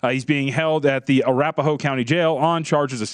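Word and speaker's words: Arapahoe: Some of the details, and this is from the, I think I'm pronouncing this correctly, Arapaho Sheriff County deputies. – Oh, come on. John, it's Arapaho.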